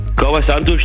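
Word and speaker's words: Kauã Santos: Santos, Kauasantos, der... – Kauasantos, den hast du gut ausgesprochen.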